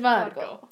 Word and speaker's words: Margeaux: And her name is Margo Templeton, but Margo is spelled M-A-R-G-E-A-U-X. Very French. – Yeah. – It's Margo. Her name is Margo. Not Margo or Mango, Wells Fargo. – Yeah, someone really said Wells Fargo. – Her name is Margo.